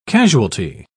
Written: casualty

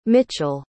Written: mitchell

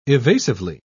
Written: evasively

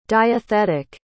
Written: diathetic

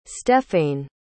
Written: stephaine

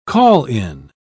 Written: call-in

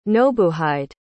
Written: nobuhide

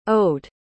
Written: owed

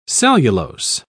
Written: cellulose

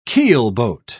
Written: keelboat

